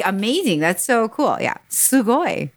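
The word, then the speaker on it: Sugoi (すごい): Sugoi. Cool. Awesome. Sugoi. – Wait, what? How do you say it? Sugoi? – Yeah, S-U-G-O-I. S-U-G-O-I.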